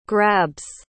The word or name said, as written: grabs